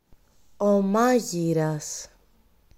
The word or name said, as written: μάγειρας